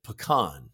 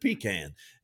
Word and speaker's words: pecan: And I noticed you pronounced it pecan, right? And there are so many people out there that pronounce it pecan. And, you know, my wife and I always have a little word over that because I grew up calling it pecan,